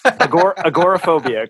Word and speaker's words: agoraphobia: agoraphobia.